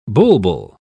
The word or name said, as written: bulbul